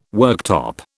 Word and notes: worktop: British English

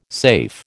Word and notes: safe: American English

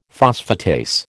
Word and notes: phosphatase: American English

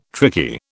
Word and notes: tricky: British English

